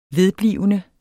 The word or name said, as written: vedblivende